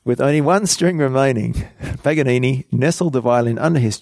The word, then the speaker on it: Paganini: It's P-A-G-A-N-I-N-I.